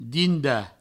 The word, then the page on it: dindar: dinda; Provençal